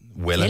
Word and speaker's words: Wella: Vella, det med W. – Ja, men det udtales det ikke Vella? – Jo.